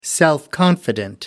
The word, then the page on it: self-confident: American English